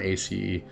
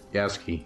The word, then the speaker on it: ASCII: It was a tabletop tennis game, and, you know, all in ACSII. Am I saying that right? And, ASCII? – ASCII.